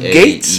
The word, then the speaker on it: Gaetz: Spelled like G-E-A-E-T-Z or some shit. – Spelled what? Oh, I had to say, nigga, what? And it's pronounced Gates? – G-A-E-T-Z.